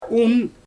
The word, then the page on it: um: Xyzyl